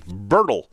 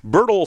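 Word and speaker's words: Berdoll: I hope I'm pronouncing that right. Bedroll. I actually thought it said bedroll, but it's B-E-R-D-O-L.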